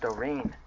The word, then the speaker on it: dorean: It's Doreen. Or Doreen. It's pronounced Doreen. D-O-R-E-A-N is the same word freely.